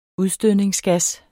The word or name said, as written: udstødningsgas